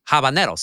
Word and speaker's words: habanero: It's not habanero. It is not habanero. It is, there's not the tilde. And look, Mexicans sometimes mess it up as well, but it's habanero.